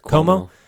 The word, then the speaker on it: Cuomo: Cuomo.